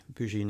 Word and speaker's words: Boujee: That's spelled B-O-U-J-E-E, Bougie Natives.